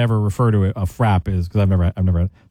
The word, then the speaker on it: frappe: National, is it FRAP? Is that how you say it? F-R-A-P-P-E, yeah. – Is that called FRAP? Yeah.